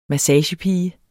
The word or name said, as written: massagepige